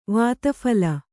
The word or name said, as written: ವಾತಫಲ